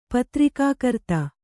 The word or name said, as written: ಪತ್ರಿಕಾಕರ್ತ